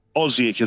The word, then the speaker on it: Osijek: Yeah, Osijek is where we are.